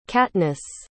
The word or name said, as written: katniss